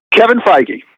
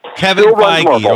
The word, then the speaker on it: Kevin Feige: Kevin Feige. – Kevin Feige. Okay. Yep. That's spelled F-E-I-G-E.